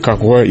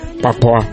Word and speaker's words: Kakwa: One of my friends in Juba has difficulties pronouncing the word Kakwa. Instead, he says, Papua.